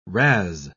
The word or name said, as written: razz